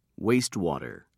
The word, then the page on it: wastewater: British English